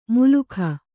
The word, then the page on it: ಮುಲುಖ: mulukha